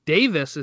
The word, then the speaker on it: Davies: They get Ben Davis is how they say it over in England, even though it's spelled Davies, and Ozan Kabak.